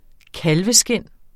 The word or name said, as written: kalveskind